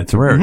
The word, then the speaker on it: Terraria: I've been playing Terraria. I think that's how you say it. – Oh, yeah. Terraria.